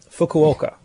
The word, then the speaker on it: Fukuoka: Yeah, Fukuoka is my pronunciation.